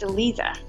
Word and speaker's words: Eliza: I think it's probably just the English in me that's trying to pronounce Eli, so instead of Lee, so yeah, but yeah, we'll get, okay, Eliza, okay, we'll get that sorted.